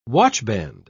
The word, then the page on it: watchband: British English